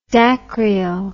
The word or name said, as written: dacryo